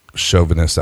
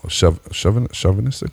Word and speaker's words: chauvinist: Chauvinistic? How do you say it? – I think so. – A chauvinist? Okay, now I'm gonna have to fucking look it up. – All right, you're chauvinist. Is that like machista? – Yeah, not yeah. Chauvinist. Can you hear it? – Chauvinist. – Yeah. I went to Google to play the pronunciation.